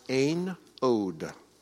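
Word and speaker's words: Ein Od (אין עוד): And it's pronounced Ein Od.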